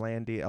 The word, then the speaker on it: Landi: I'm at Eric Landy. Use my first name. That's Eric spelled with a K. Last name Landy, L-A-N-D-I, on all the social media garbage.